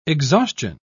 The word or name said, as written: exhaustion